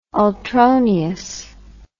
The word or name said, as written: ultroneous